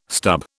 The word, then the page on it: stub: British English